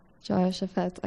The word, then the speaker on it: Jehoshaphat: The next example was King Jehoshaphat. Am I saying that right? Jehoshaphat, okay.